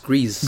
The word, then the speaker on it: Gris: And what they did was they hooked up the Switch and played Gris? – I think it's pronounced Grease.